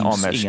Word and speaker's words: Eames: Nathan Eames, vagy hogy, hogy, hogy ejtik szerinted így jól a nevét? – E, Ames, Ames, Nathan Ames. Ames.